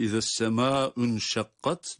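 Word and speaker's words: izessemâ unşakkat (إِذَا السَّمَاءُ انْشَقَّتْ): Halbuki izessemâ unşakkat doğrudur. Yani okumayı devam ettirdiğimiz takdirde izessemâ unşakkat olarak okunur ama izessemâ'u hatta kelimeleri tek tek ele alırsak izâ essemâ'u inşakkat şeklinde kelimeler tek tek bu şekilde okunur.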